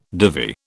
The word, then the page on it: divvy: British English